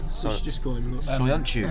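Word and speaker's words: Söyüncü: Soyuncu. – Soyuncu.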